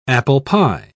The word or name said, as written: apple-pie